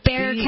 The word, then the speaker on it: Bexar: How is it supposed to be pronounced? – It's Bexar. It's a Native American word, really.